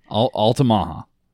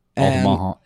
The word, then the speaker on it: Altamaha: So I pronounce it Altamaha. – Altamaha.